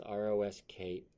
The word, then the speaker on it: Roscoe: I'm like, it's Rosko. Like, did you watch Dukes of Hazzard growing up? – No.